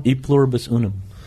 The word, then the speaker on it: E pluribus unum: How is it said, though? – E pluribus unum.